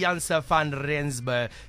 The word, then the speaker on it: Jansen van Rensburg: Yes, Libby Jansen van Rensburg.